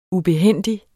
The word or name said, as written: ubehændig